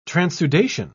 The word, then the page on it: transudation: British English